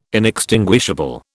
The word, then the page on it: inextinguishable: British English